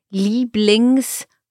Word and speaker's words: Lieblingssport: So here pronunciation, Lieblingssport. – Ah, okay. – Ja? Because although it's in the middle of the word, it's a compound noun and then we treat the Sport as if it were at the beginning.